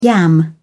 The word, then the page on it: yam: British English